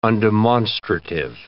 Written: undemonstrative

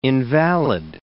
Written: invalid

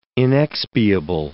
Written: inexpiable

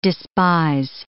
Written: despise

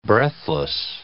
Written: breathless

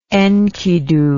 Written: enkidu

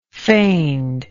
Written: feignedness

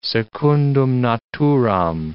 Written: secundum naturam

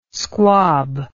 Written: squab

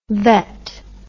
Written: vette

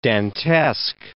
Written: Dantesque

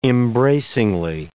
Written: embracingly

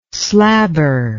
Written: slabber

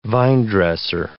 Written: vinedresser

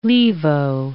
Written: levo

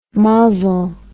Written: mozzle

